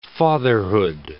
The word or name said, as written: fatherhood